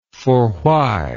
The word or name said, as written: forwhy